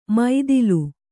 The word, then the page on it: ಮೈದಿಲು: maidilu